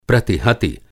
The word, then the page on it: प्रतिहति: Hindi